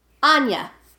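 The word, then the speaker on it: Áine: Aisling B, whose name is not spelled the way you say it, and her character's name is Anya, but it's spelled A-I-N-E. Anya.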